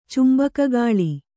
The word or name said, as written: ಚುಂಬಕಗಾಳಿ